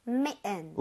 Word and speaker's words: mitten: 그래서 얘 같은 경우도 S를 써주시고 I의 강세를 많이 줘서 사실 들을 때는 메은, 은처럼 들릴 확률이 높아요. 그러니까 미튼 해주면 좋을 텐데 메은 이렇게 많이 합니다. 미튼. 이거 발음 한번 해볼게요. – 네. – 메은, 메은. – Mitten.